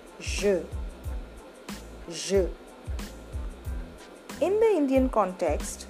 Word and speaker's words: je: When you pronounce je, you have to lightly brush the roof with a slightly rounded tongue, enough to let some air pass. Try it. Je, je, je.